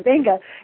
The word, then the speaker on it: Beqa: it's an island called Benga, and it's spelled B-E-Q-A.